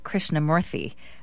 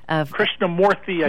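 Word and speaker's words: Krishnamoorthi: I think it's Raja Krishnamurthy. – Krishnamurthy, I think, is the right way to pronounce it, although when I was on TV with him last night, it sounded different.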